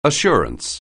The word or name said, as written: assurance